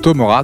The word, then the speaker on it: Tomora: Voilà, donc l'album s'appelle Come Closer, c'est donc Tomora, T-O-M-O-R-A, comme ça se prononce,